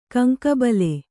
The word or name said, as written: ಕಂಕಬಲೆ